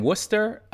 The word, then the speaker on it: Worcester: I was born in Worcester, and then I was raised for a while in Boston.